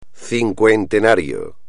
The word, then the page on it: cincuentenario: espagnol